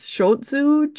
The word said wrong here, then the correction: Schutzhund: Schutzhund, yeah.